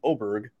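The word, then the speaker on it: Åberg: It's not Aberg, it's Oberg. Will you find the letter A, but pronounced O, Berg, on your lineup, Quagnus? – I'm clicking.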